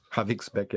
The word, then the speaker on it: Havixbeck: Ich hoffe, dass die Leute in Habeck-Wix bald wieder gut schlafen. – Havixbeck, ja. – Oh, Havixbeck? Oh, sorry, habe ich es falsch gesagt.